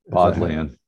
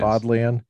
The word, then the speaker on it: Bodleian: for a Oxford scholar who had been in the, how do you say it? The Bodleian Library. – Bodleian. – Yes. Bodleian.